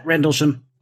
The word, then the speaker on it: Rendlesham: And you'll forgive my poor South Texas pronunciations of the various names, but I believe it's Rendlesham. Is that how you say it? – Yeah, Rendlesham.